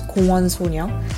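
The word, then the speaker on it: Gwon Sonyeo (공원소녀): So, the correct pronunciation of GWSN in Korean is Gwon Sonyeo, for which Gwon is park and Sonyeo means girls in English, so girls in the park.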